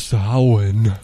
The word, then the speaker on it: Samhain: But he's also going to talk about what here in the United States, people call Samhain. There's a more proper pronunciation of this. – Samhain.